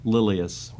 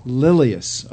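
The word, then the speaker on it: Lilias: So help me specifically, how do you pronounce her first name? – Lilius. – Lilius, okay. Mm-hmm.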